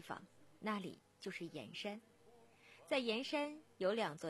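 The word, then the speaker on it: 铅山: 我们的脚步来到江西盐山古镇。说到盐山，其实盐字就是铅笔的这个铅字，在这里呢要读成盐。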